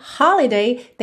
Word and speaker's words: holiday: Lembrando que holiday tem a sílaba tônica na primeira sílaba, holiday, holiday. Mas aqui na música ganhou uma ênfase por conta da divisão rítmica e melódica.